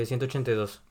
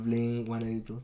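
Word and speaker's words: Blink-182: Porque se supone, según Tom, después de muchos años, se pronuncia Bling. 182. – No, Bling 18, como 18. 2. 18-2. güey. O sea, como 18-2. Pues.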